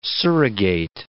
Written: surrogate

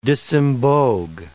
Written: disembogue

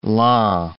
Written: Laon

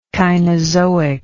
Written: cainozoic

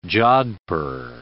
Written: jodhpur

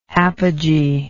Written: apogeic

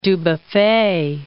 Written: Dubuffet

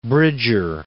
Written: Bridger

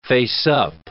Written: faceup

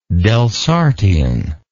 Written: Delsartian

